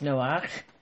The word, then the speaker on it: Noach: So his Hebrew name is Noach, and it has that ending. And we pronounce it just Noah.